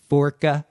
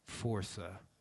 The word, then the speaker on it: Força: And they're like, Forca. And I'm like, Força. Force with an A. – Yeah. – Portuguese for strength.